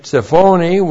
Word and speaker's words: tsiphoni: F is joined to the adjective tsiphoni with its definite article. The adjective tsiphoni looks like this. T-S Shava P-H-O, that's long O-N-I. Tsiphoni. Tsiphoni comes from the noun tsaphon.